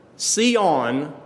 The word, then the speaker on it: Sion: And I just want to run through those real quick so you can see that Mount Zion, Sion, as it would be pronounced in the Greek, Mount Sion is something that you are to see by faith.